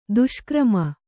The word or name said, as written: ದುಷ್ಕರ್ಮಿ